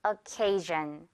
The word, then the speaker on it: occasion: 발음은요, S-I-O-N 들어가 있고 occasion 이렇게 발음을 합니다. occasion.